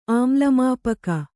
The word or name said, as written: ಆಮ್ಲಮಾಪಕ